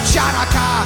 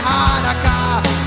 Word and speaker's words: Hanukkah: It's not pronounced Chanukka, the C is silent in Hanukkah.